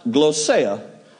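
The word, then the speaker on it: glossia (γλῶσσα): Now, the tongue is a Greek word pronounced glossia.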